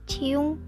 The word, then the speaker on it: Chyung: Tulisannya C H Y U N G. Bacanya apa? Ciung.